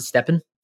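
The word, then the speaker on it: STEPN: Is that how you pronounce it, Steppin? – Yeah, that's how I've been pronouncing it anyway.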